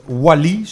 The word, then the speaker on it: Oisly: En die komt dan uit uh, Sauvignon Wally, zo spreek je het uit. Oisly schrijf je.